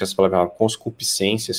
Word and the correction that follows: concupiscência: sei lá, conscupiscência, é isso mesmo? – Concupiscência.